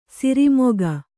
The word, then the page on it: ಸೋರೆಮೊಗ: sōre moga